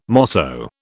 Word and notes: mosso: British English

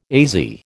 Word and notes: AZ: American English

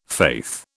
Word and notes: faith: British English

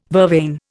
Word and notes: vervain: Indian

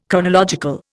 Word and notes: chronological: Indian

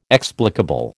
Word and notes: explicable: American English

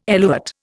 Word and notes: eluate: Indian

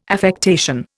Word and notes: affectation: Indian

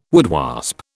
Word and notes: woodwasp: British English

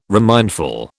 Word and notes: remindful: British English

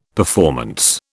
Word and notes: performance: British English

